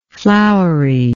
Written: flouriness

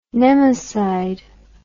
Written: nemacide

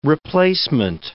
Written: replacement

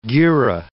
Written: gerah

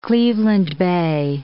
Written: Cleveland bay

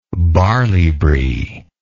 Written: barley bree

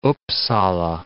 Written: Uppsala

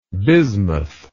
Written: bismuthal

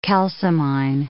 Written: calcimine

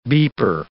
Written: beeper

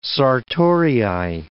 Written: sartorii

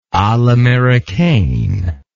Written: a l'americaine

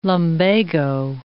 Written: lumbago

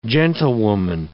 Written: gentlewoman

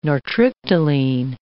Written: nortriptyline

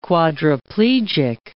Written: quadriplegic

